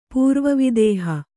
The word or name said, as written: ಪೂರ್ವವಿದೇಹ